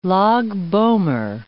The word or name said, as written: Lag b'Omer